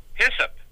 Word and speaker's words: hyssop: What about Aesop? Am I saying that right? The Bible had it as... – Hyssop. – Hyssop, yeah, that's what I'm trying to remember.